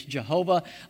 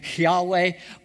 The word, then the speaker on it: Yahweh: We now, sometimes it's been translated traditionally for years as Jehovah. Only now we know a more accurate pronunciation might be Yahweh, but they didn't say Yahweh out loud.